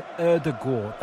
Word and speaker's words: Ødegaard: Arsenal are preparing to bring on their new boy, Martin Ødegaard.